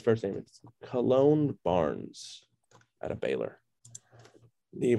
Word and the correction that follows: Kalen: ran the Kalen Barnes. – They ran the unofficial 4-2-1.